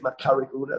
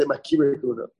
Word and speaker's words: Makari Kahuna: So Amar Rav said, Makiri Kahuna Ulaviya, the two ways of pronouncing it. The correct way is Makari Kahuna, that's what Rashi says. And the Yeshiva's Dominicans say Makiri Kahuna.